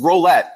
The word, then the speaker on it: Rollett: Did I say your last name right? – It was close, man. It's just Rollett. It's just super, Rollett. – Just Rollett. You got it, man.